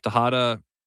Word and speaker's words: Texada: Tejada, and it's spelled T-E-X-A-D-A.